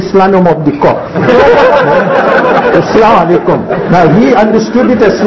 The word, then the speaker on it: Assalamu alaikum: So we should pronounce it properly. Assalamu alaikum.